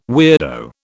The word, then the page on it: weirdo: British English